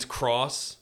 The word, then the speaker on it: Street Fighter X Tekken: So it's like you see all those games that's like Street Fighter X Tekken, but it's Street Fighter Cross Tekken is how they pronounce or would say it.